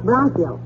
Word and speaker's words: bronchial: You heard Doc Gamble tell me if I didn't cut out cigars, my Chronicle tubes would get stopped up and I would... – The word is Bronicle, dearie, and it's pronounced Bronchial.